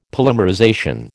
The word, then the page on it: polymerization: American English